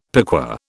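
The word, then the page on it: piqué: British English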